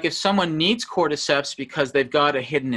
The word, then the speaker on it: cordyceps: And it's about the benefits of taking cordyceps. Am I pronouncing that right? – Cordyceps.